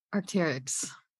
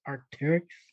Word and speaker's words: Arc'teryx: Arc'teryx. – Oh, wow. That's how you say it? Arc'teryx? – Yeah, you didn't know that? – No, I thought it was like Arc'teryx. Arc'teryx, bro.